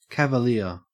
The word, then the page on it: cavalier: Australian English